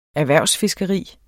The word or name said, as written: erhvervsfiskeri